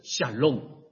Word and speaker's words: shalom: 今天结束以后也请你记住，读音读准一点，我也是练了好多次，好记是 shalom。在犹太人里面 shalom 他们已经用了几千年这个词，而且 shalom 不管是见面的时候啊，分手的时候都可以讲 shalom，主要是祝福问候赐福， shalom 可以包办一切，讲 shalom 永远不会错。